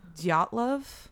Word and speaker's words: Dyatlov: I believe the way that it's pronounced is Dyatlov. The Dyatlov Pass incident. – Okay. – How do you spell that? – It is Russian, so technically it's Cyrillic, but Dyatlov.